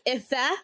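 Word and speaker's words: Ifé: So I was like, my name is actually spelt with an accent on the E, so it's Ife.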